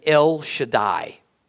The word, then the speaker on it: El Shaddai: Because up to the time when God gave Moses his personal name at Mount Sinai, God was most known, we're told, as El Shaddai, with emphasis on the L part.